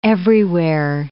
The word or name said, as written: everywhere